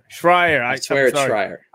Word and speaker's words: Schreier: There was a report from Jason from Kotaku, Jason Schreier, I always pronounce his name wrong. – Schreier. – I'm sorry, Jason. I always butcher your name, man.